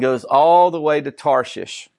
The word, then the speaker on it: Tarshish: I said, surely there's a better way to pronounce that. There's not. It's Tarshish. Now, that's modern day.